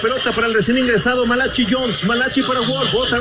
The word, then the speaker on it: Malachi: But it's Malachi. – It is that is how it's spelled.